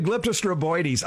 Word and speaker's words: glyptostroboides: Metasequoia glyptostroboides. I just like saying that. – Glyptostroboides. – Stroboides? – You're missing it. It's O-I-O. I-O-I-D-E-S. – Metasequoia glyptostroboides. – Yeah. – Okay. – One more syllable.